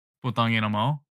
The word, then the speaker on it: Putang ina mo: Putang ina mo. – Yeah. – P-U-T-A-N-G I-N-A M-O. Putang ina mo. – You're saying that shit so fast, bruh. Like, yeah. – Putang ina mo.